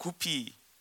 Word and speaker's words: 구피: 그런 은, 사도 있는지는 모르겠는데 어, 한 번은 누가 커피 그러니까 구피 한국 바, 발음은 구피죠.